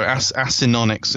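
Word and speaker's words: Acinonyx: So asynonyx is the pronunciation I should have used for cheetahs.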